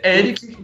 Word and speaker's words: Eric: Na verdade, é só descobrir como é que fala meu nome e aí você tem o nome, que é Eric, que é E-R-I-C, é, o Eric tradicional, e Peleias, que você escreve Pelé, tira o acento, coloca IAS.